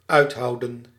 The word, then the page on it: soutenir: /sut.niʁ/; /su.tə.niʁ/